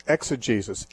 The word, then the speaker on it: exegesis: Yeah, it's exegesis, E-X-E-G-E-S-I-S.